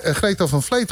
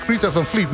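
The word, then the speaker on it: Greta Van Fleet: Ik denk Greta van Vleet moet je het uitspreken.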